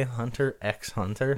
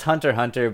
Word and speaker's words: Hunter x Hunter: No, it's Hunter Hunter, but if you don't know how it's spelled, it's harder to search for. They just put an X in there for some reason.